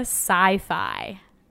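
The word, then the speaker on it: Psi Phi: Omega Phi Phi. – It's Omega Sci-Fi. – Like, okay, sci-fi. I was right with the one.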